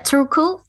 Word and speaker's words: Turku: To, to uh, did I say it right? What is it? Eh, yeah, Turku, and yes. uh, that is how the Finnish Finnish people pronounce it, and the Swedish actually call it Åbo.